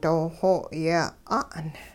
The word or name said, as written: dooxoo yeh 'a'n